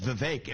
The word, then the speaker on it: Vivek: I say my name's Vivek. It rhymes with cake.